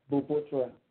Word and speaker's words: BUPOTRA: The name of the organization is called Building Potential for Transformation, and the short name is BUPOTRA. That's how it is pronounced.